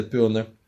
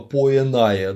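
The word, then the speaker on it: poenae: Пёны. Читается именно так, да, это не стипулятио поэнае, да, диграфы, дифтонги соблюдайте, пожалуйста, это стипуляция пёны.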